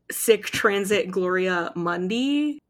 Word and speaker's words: Sic transit gloria mundi: We're talking about the season one finale titled Sick Transit Gloria Mundi. I don't speak Latin. Thank you. – No, that's good though. You did a good job. That is very good pronunciation of Latin.